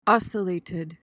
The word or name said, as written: ocellated